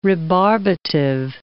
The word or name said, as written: rebarbative